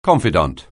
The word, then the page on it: confidante: anglais